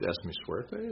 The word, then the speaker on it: Es Mi Suerte: So the second one, I'll pronounce the second album as Es Mi Suerte. Does that sound pretty accurate? – That sounds very good, yes.